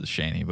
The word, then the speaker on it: Shaney: You know her name is Shane, right? – It's not Cheney? – No, it's spelled as Shaney, but it's Shane.